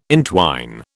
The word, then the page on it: intwine: British English